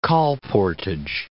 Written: colportage

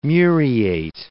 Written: muriate

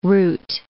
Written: route

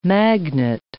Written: magnet

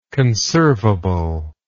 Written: conservable

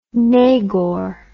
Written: nagor

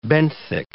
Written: benthic

